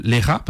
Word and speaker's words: Leja: Donc, Joël Lera, parce que du coup, voilà, ça se dit comme ça, mais ça s'écrit L-E-J-A, si vous cherchez.